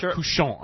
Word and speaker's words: cochon: French boy, how do I pronounce that? – Cochon. – Couchon, I'm sorry. Couchon is pig.